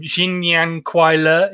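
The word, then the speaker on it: Jin Nian Kuai Le (新年快乐): Jin Nian Kuai Le is how you say Happy New Year,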